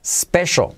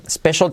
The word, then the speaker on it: special: Special. Special tiene la letra clave SH. Sh, special. Aunque se escribe una C con una C, es special.